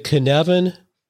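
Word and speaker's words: Cynefin: But the Kinevin, did I say that correctly? – You did.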